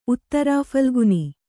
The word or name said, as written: ಉತ್ತರಫಲ್ಗುನಿ